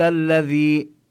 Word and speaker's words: الذي: أفرأيت الذي، والمطلوب أن ينتبه الإنسان إلى حرف الذال، يعني أن لا ينطق به مثل الزاي، بما أنه بالكتابة في فرق بين الذال والزاي، كذلك باللفظ ينبغي أن يظهر الفرق. الذي تولى، وعند الذي الذال مكسورة وبعدها حرف الياء، فينبغي أن نمد الصوت قليلا مدا طبيعيا.